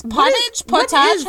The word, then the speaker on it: potage: Potage? How do you say that? – Potage. – P-O-T-A-G-E.